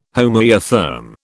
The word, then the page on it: homoeotherm: British English